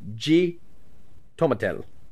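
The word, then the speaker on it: xitomatl: It's X I T O M A T L, which is pronounced G tomatel or tomatel tomatel tomatel Oh my god,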